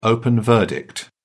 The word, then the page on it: open verdict: British English